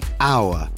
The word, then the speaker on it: hour: Un'ora si pronuncia come il nostro. Tra il suono di hour e di hour non c'è alcuna differenza. Come se la H non ci fosse.